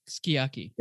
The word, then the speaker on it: sukiyaki: So in same for sukiyaki, it's just pronounced sukiyaki. – Yeah, sukiyaki. – Yeah, the U many times is not exactly not used, but it's said faster.